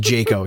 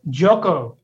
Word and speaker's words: Jocko: Pronounce that Jocko. – Oh, that's Jocko. An O. Okay, all right. It could just be my contrast. It could just be my Oh, there it is. It's an O.